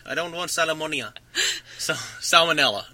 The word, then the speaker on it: salmonella: No, you said it right, but my friend at the convenience store goes, man, you're going to get me sick. You're going to give me salmonia.